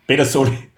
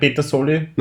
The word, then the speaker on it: Pedersoli: Pedersoli. – Pedersoli, ja. – So Pedersoli, so wie Peter.